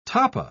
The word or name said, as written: tapa